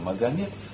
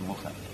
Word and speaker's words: Мухаммед: Так вот, является ли Мухаммед, намеренно не говорю «Магомед», от этого слова где-то надо отвлекать, оно французское, это французы так сказали, и оно слишком отличается от того, как это слово следует произносить. В соответствии с арабским языком, все-таки Мухаммед.